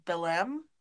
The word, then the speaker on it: Belém: It was called Belém. – Mm. – I'm probably pronouncing that completely wrong. B-E-L-E-M. – That's how we pronounced it when we were there.